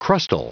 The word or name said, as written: crustal